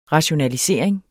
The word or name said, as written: rationalisering